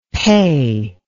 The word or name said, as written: peh